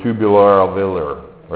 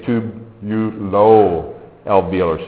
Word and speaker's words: tubulo-alveolar: Like, just don't say tubular-alveolar, right? Make sure you say tubulo-alveolar, so they know you're putting tubular and alveolar together.